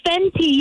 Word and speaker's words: Fenty: So my granddaughter told me all about the brand called Fenty. Am I saying that right? – What was the brand name? – Fenty. – Oh, Fenty, yes.